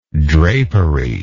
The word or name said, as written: draperied